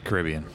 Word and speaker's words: Caribbean: Caribbean.